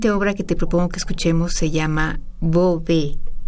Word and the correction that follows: Bobé: No sé si se pronuncia así. A ver, dilo tú. Bobé, sí, Bobé, eh, más bien.